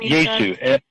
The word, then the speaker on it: Yaesu: And then you had mentioned, how do you pronounce it? Yaesu or Icom? – Yaesu. Y-A-E-S-U. – Y-A-E-S-U. Okay.